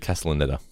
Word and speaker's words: Castellaneta: It's actually Castellaneta. – Castellaneta. Then clearly no. I learned nothing from this episode. – I think I've been saying Castellaneta as well, but he says Castellaneta. – Castellaneta.